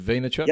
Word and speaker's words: Vaynerchuk: Yeah, talking about Gary Vaynerchuk, that's how you pronounce his surname, right? Is it Vaynerchuk? – Yep.